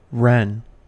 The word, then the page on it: wren: American English